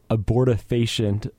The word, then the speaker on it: Abortifacient: We started out with Abortifacient by AIDS Wolf. I think that's a better pronunciation.